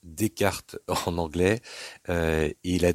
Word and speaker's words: Descartes: Alors la prononciation est assez simple, c'est Descartes.